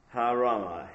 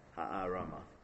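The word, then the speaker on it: Harama (הערמה): Harama. Hey, ayin, resh, mem, hey. Ha-arama.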